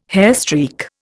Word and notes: hairstreak: Indian

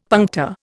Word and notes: puncta: Indian

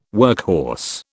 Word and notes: workhorse: British English

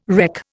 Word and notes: reck: Indian

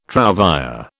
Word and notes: trouvère: British English

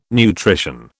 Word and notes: nutrition: British English

